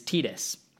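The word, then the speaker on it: Tidus: A lot of people pronounce it Titus, but I think the correct Japanese pronunciation is Titus.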